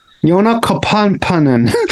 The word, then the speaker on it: Joona Kapanen: She just said you have to pronounce both the O's and both the P's. – Joona Kapanen.